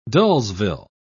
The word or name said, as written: dullsville